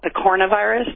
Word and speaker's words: picornavirus: You call them pico, like family is it's called picornavirus. That's how you pronounce it. But if you look at the spelling, it's picorna virus.